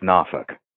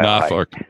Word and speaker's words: Norfolk: I used to I went to law school in Virginia, and it's actually pronounced Norfolk. – So oh, okay. just FYI, Norfolk.